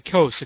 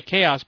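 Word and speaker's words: Kos: I've been calling it Daily Chaos because I thought that's what it was, but apparently the guy's name is Marcos, and he says, no, it's not pronounced chaos, it's pronounced coast.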